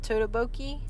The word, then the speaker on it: tteokbokki: And I got some frozen tteokbokki. I don't know if I'm saying it right. Tteokbokki. That's it. Tteokbokki.